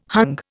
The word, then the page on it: hung: Indian